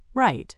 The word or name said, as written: right